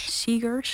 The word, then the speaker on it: Segers: Een nummer van Piet Segers, zeg ik dat goed? – Segers, sorry, ik heb dyslexie.